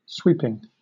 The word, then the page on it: sweeping: British English